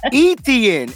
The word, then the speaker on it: Etienne: So Travis Etienne is how you say his name. Etienne, right? – Okay.